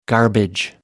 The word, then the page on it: garbage: American English